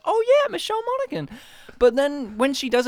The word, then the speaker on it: Monaghan: It's great because let's not forget that Michelle Monaghan, if that's how you pronounce her name, because I've heard it pronounced Monaghan. – I've heard it pronounced Monaghan, Monaghan, and Mahogany.